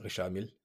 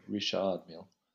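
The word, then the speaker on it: Richard Mille: do you pronounce it Richard Mill? – Isn't it Richard Mill? – Richard, yeah. Over there, you'd pronounce it Richard Mill.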